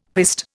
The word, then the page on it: piste: Indian